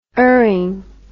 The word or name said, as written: urning